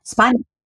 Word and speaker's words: Spagna: Yeah, Spagna.